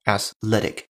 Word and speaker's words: athletic: athletic，注意它的发音，athletic，athletic 啊，这是一个跟 athlete 重音放的不一样地方的单词，一定要注意这一点。